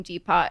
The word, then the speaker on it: Depot: Well, I'm obviously, as I said, taking found objects from Home Depot and Lowe. – It's Depot. – I believe the T is silent.